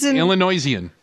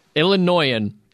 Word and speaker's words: Illinoisan: Illinoisian. – Illinoisan. – Illinoisan. – Yeah. There's no I that you, you threw an extra I.